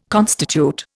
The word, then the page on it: constitute: Indian